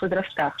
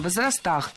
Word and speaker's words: возрастах: Мы слышали варианты возрастам, возрастах, но они неверные, должны быть возрастом, возрастах, возрастов и в именительном падеже возрасты.